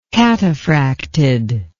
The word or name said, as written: cataphracted